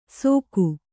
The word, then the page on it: ಸೋಕು: kannada